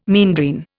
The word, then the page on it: meandrine: Indian